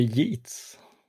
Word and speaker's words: Yeats: Är det Yeats? – Mm. Är det så han heter? – Rätt, det är alltså William Butler Yeats som han heter då.